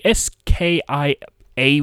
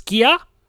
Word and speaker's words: Skia: S K I A with a little doodah about it, so I'd assume it's Skia.